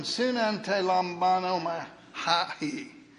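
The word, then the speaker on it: synante lambanoma hahi (συναντιλαμβάνεται): Here's one, synante lambanoma hahi. Now, the emphasis should be on the bon, if that helps you any. It's about a 7-syllable job, and it's in Greek, so you really ought to impress somebody.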